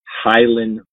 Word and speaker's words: Hylan: It's on the, it's spelt H-Y-L-A-N, so 2 Highland Boulevard.